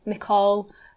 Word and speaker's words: Nicoll: But I mean, Nicole, people do see the C-O-L-L, and that is call in like there's an Isle of Call in Scotland, which is how you'd say that.